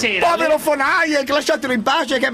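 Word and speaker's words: von Hayek: Sì, ma mi ritrovo nelle idee di Fonage. – Chi la dico questo? – Danna. – Semmai è Fonajek. È Fonajek. – Sì, sì, hai ragione, Fonajek.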